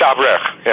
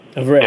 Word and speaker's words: Avrich: Yeah, it's Avrich. Yeah. – Avrich. – Yeah. – Beautiful. Okay. – Yeah, in English, Avrich. In Hollywood, I'm known as Robert Avrich. Yeah.